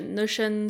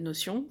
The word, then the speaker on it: Notion: Notion, c'est la prononciation anglaise, en français Notion, ouais, tout simplement.